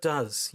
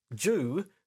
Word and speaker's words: dew: Which is fine if you pronounce the word D-E-W, do, but when you pronounce it Jew, it suddenly becomes a hate crime.